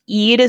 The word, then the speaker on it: Eid: Fittingly, it rhymes with feed, and Matthew got it. Eid is what they're looking for, and I knew that that was what they were looking for.